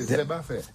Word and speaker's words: Zeba Fer: Et à Coyol, c'est Zeba Fer. Zeb, comme herbe, à fer. – Zeba. – Parce qu'il est chargé en fer aussi. – À Zeba Fer. – Ouais. On prononce pas les R, oui.